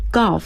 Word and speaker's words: Gulf: Gulf 和 Gulf 好像发音很接近的哈，跟高尔夫那个词，对，高尔夫发成 Golf，这个发成 Gulf，一道注意是三角的啊的发音。